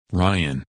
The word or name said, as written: Ryan